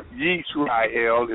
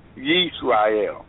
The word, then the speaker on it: Yisrael: Blessed be the Lord that hath given rest unto his people Israel, or Yisrael is the way it's pronounced in Hebrew, Yisrael, according to all that he what promised.